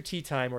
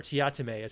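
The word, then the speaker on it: Teatime: But the book opens with Mr. Tea Time, or Tiatame, as he likes to pronounce it, receiving a contract from some shadowy ethereal figures at the Assassin's Guild for Santa.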